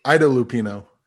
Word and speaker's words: Ida Lupino: I know the name and I forgot how to pronounce it correctly. Ida Lupino. – Ida yes. Lupino. Yes.